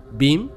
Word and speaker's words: BEAM: Bim, Bim Ship, Bim se escribe B, E, A, M.